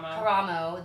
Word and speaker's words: Karamo: Karamo, the beautiful black man who is, like, stunning.